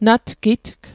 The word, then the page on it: natgitg: Mi'gmaq